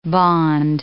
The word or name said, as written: bond